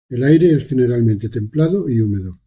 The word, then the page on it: templado: Spanish